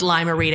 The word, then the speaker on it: Lime-A-Rita: It's Limerita, and it's spelled like this. Lime-A-Rita.